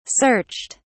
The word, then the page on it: searched: British English